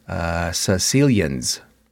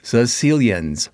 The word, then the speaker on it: Caecilians: They got a pronunciation here. Caecilians. That's how you pronounce it. Caecilians.